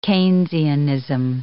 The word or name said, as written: Keynesianism